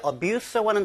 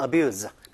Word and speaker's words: abuse: 보통 이제 abuse라고 하는 단어가 명사나 동사로 쓰이는데요. 물론 동사로 쓸 때는 abuse, 그리고 명사로 쓸 때는 abuse, 이렇게 발음이 좀 차이가 나긴 하겠습니다만, 그게 세 가지 종류를 생각하시면 되겠습니다.